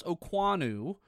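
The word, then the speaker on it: Okuayinonu: It's pronounced Okwuonu, but it's O-K-U-A-Y-I-N-O-N-U. Okwuonu is the way he wants it, and I hope I'm doing that right.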